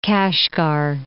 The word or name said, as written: Kashgar